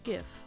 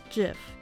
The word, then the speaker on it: GIF: As the whole internet is arguing about the correct pronunciation, the creator Steve Wilhite joined the debate and declared that it is pronounced GIF, although the majority pronounce it GIF. As a result, this word is officially pronounced with both soft and hard version interchangeably.